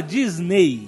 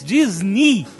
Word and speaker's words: Disney: O EY no final da palavra de Disney, na verdade não se fala Disney, se fala em inglês, na pronúncia em inglês, Disney. Tem o som de I.